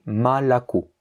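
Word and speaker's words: Malako (молоко): Donc ce mot qui s'écrit Moloko a l'accent tonique sur le dernier O et donc il va se prononcer Malako.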